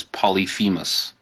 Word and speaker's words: Polyphemus: The common pronunciation is Polyphemus.